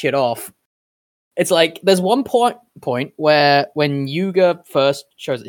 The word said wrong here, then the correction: Yuma Kuga: Is that his name, Yuga? Am I saying that right? The new guy? – Yuma Kuga. – Yuma Kuga, right, sorry.